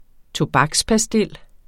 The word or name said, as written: tobakspastil